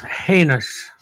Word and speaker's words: heinous: Commit this hyenas crime. – Hyenas. That's the proper pronunciation of the word is heinous.